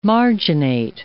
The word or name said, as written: marginate